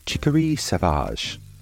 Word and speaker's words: chicorée sauvage: Wild chicory in France is pronounced chicory sauvage and spelt chicory, C-H-I-C-O-R-E-E, sauvage, S-A-U-V-A-G-E.